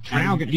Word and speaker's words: Traralgon: I'm still not saying it right. I was calling it Traralgon. – Yeah, okay. – Like Traralgon. You got it through the nose.